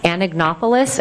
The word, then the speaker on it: Anagnopoulos: My name is Dina Anagnopoulos, and I'm the attorney representing Dr. Farr today. – Could you say your last name again? – Anagnopoulos, and I can spell that for you. – Okay. – A-N-A-G-N-O-P-O-U-L-O-S.